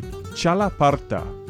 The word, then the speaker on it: Txalaparta: And another instrument that I really wanted to mention, not easier to pronounce for sure, but it's called the Txalaparta. It's spelled, now take a look at this, T-X-A-L-A-P-A-R-T-A, Txalaparta.